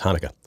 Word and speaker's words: Hanukkah: Hanukkah.